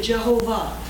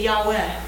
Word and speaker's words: Yahweh: For a while, we put an E in here and an O here and an A there, and we pronounced it Jehovah. But then scholars said maybe it should be an A here and an E there, and now we say Yahweh.